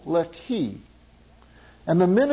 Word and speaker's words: Lehi: Now the Philistines went up, encamped in Judah, and deployed themselves against Lehi, is how it's pronounced. Lehi is in the English text, but it's Lehi.